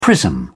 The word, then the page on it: prism: British English